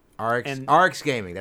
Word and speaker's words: Arekkz: It's hard to pronounce. It's a weird one to pronounce. – Yeah, Arx is Gaming. Arx Gaming. That's how you Arx Gaming.